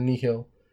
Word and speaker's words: Nihil: So we know the Nihil, I'm going to use the Latin pronunciation, the Nihil, they have some power over hyperspace.